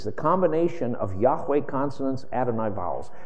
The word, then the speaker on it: Yahweh: Just know that the real name is Yahweh. And by the way, this is, most scholars today really believe, high probability that that's how that name is pronounced, going back to ancient documents.